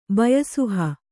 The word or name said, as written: ಬಯಸುಹ